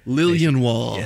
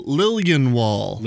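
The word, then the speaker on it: Liljenwall: Lilienwall, so the J is just a Y. – Lilienwall. Yeah. Okay, I would have said Lilienwall and just kind of skipped over the J. – That's what a lot of people do, or people just pronounce the J. That's the most common one.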